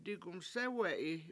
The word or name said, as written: digumsEwe7i